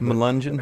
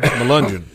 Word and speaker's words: Melungeon: I'm saying it wrong, I think. But apparently, if it were pronounced correctly, I might know what you're talking about. – Melungeon? – Melungeon. – Melungeon. – Melungeon. – Yes.